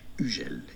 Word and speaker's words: Hugel: Anyway, I went on Google to listen to the correct pronunciation. Listen to this. – French, this is a name of French origin. It's a surname. It's the name of a fairly popular DJ in French. Hugel is the French pronunciation.